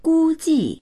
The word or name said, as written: gu1ji4